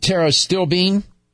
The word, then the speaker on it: pterostilbene: It's spelled T like Paul, T-E-R-O, S-T-I-L-B-E-N-E. – S-T-I-O? – P-T-E-R-O, S-T-I-L-B-E-N-E. – Mm-hmm. So you pronounce it Tara Stillbean? – The P is silent.